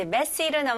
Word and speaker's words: messy: 근데 중요한 거는 강세입니다. 여기서는 첫 음절에 강세를 주시면 돼요. 그래서 messy가 아니고 messy, messy 해볼게요.